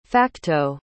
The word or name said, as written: facto